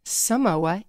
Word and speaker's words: Samoa: Samoas also, little known fact, if you think about Samoa, it happens to rhyme with Jason Momoa.